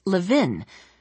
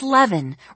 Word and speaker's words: Levin: During roll call, their teacher, Miss Malintantis, had mispronounced Jessie's last name as Levin, rhyming it with the pin, and Jessie had surprised herself by speaking up and saying, "It's Levin, rhymes with heaven."